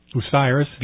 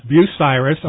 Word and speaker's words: Bucyrus: But I found it fascinating because your dad, Bill, is from Ohio, and I always mispronounce the name, but it's Bucyrus. Bucyrus, Ohio, mm-hmm.